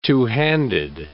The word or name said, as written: two-handed